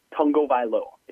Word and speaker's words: Tagovailoa: And it's Tua Tagovailoa, if you're wondering. – Tagovailoa.